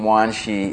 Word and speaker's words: Wanshi: And in Hongzhi is the Chinese pronunciation, Wanshi is the Japanese pronunciation of Hongzhi's name, and it became known as the Wanshi school in Japan.